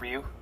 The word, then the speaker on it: Ryu: In Japanese, it's actually pronounced Ryu. – Okay. – It's said really fast, so it almost sounds like Ryu, but they just say it like Ryu.